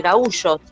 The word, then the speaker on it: gaúcho: A mí lo que me llamaba la atención es, bueno, la gente de ahí, de esa zona, se llaman gaúchos. ¿Se escribe igual que gaucho? O sea, es gaucho. Se visten igual. Toman mate. Pero se dice gaúcho.